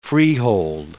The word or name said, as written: freehold